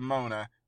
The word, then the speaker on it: Moner: I found a new crush in Isabela Moner. – It rhymes with boner.